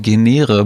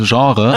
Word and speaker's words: Genre: Da habe ich das Wort Genere benutzt, Genre, ah. – habe es aber Genere ausgesprochen und äh, ja, direkt hat die ganze Klasse gelacht, weil ich es einfach noch nie gehört habe, das Wort vorher.